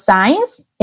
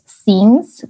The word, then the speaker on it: since: Y el since, que bueno, en esto o sea, es, muy común pronunciar los signs. Eh, recuerden que es since la pronunciación.